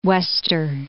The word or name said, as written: Key Wester